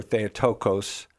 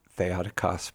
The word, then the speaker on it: Theotokos: And the bottom line is, what we have to understand in our Catholic theology, the dogma of the Theotokos, or Theotokos, as most people pronounce it, I argue it's actually Theotokos, but that's neither here nor there.